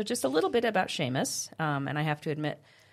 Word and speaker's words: Seamas: He's like, it's Seamas. I'm like, oh. I had never heard it, didn't know how to pronounce it. So it is Seamas.